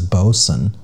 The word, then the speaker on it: boatswain: Isn't that how you say the word that's spelled like boatswain? – Oh. – That's a real thing. – I know boatswain. I thought you just said boatswain, though. – Yeah, I heard it pronounced as bosun.